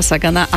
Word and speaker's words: Sagan: Čo by sme mali vedieť o tomto astronómovi, ktorého priezvisko sa síce píše rovnako ako nášho Petra Sagana, ale ono sa to vyslovuje Sagan.